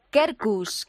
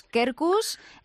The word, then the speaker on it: Qerkus: Bueno, pues una nueva palabra que tienes que incluir en tu lenguaje, en tu vocabulario, que es Kerkus. Kerkus es Q-E-R-K-U-S. – tal que, suena. – Que en latín habría que pronunciarlo quercus, que sería encina.